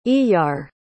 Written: Iyar

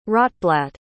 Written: Rotblat

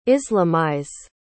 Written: islamize

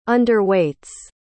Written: underweights

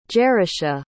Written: Jerusha

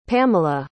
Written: pamela

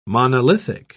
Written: monolithic

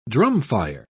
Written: drumfire

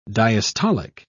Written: diastolic

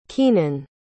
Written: keenan

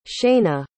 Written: shanae

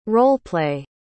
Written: role-play

